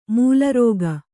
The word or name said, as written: ಮೇಲಾರೈಕೆ